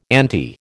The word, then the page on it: ante: American English